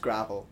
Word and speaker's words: Gravel: It's actually pronounced Gravel. – Gravel? – Yeah, it's spelled Gravel. And I always Gravel. – Keep calling it, but I've been told many times by Ben that it's just Gravel.